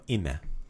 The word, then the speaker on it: ime: There you go, ime. – Ime. – Right? It looks like it says I'm. But ime, that's how you pronounce it in Croatian.